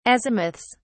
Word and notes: azimuths: British English

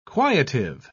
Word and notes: quietive: British English

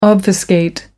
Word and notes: obfuscate: American English

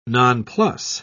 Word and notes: nonplus: British English

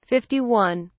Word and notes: 51: British English

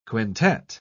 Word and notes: quintette: British English

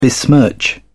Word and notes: besmirch: British English

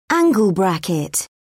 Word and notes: angle bracket: British English

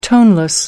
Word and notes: toneless: American English

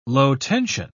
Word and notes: low-tension: British English

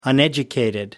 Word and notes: uneducated: American English

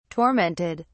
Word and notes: tormented: British English

